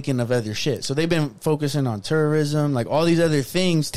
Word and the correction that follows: tourism: Tourism, yeah, but not terrorism. – You really gotta pronounce it better.